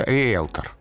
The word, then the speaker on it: риелтор: А, произносится риэлтор. Риэлтор. – То есть э, звук «э». – Да. – Произносится риэлтор, а пишется буква Е, но ну, точно так же диета произносится.